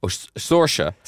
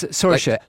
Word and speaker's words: Saoirse: Saoirse? – Saoirse. – Oh, it's Saoirse. – Saoirse Ronan. – Saoirse Ronan. – Saoirse Ronan. – S-A-O-I-R-S-E. S-A-O-I-R-S-E.